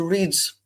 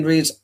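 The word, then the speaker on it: Riddes: So I flew to Geneva Airport and I took the train direct, switched at Martigny and took another train on to Reed. I think that's the correct pronunciation. It's R-I-D-D-E-S.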